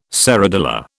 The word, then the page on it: serradilla: British English